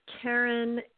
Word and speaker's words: Karyn: It's Karyn, K-A-R-Y-N.